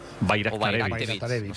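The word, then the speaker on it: Bajraktarević: Bayraktarevich. O sea, la J la conviertes en ahí, Bayraktarevich.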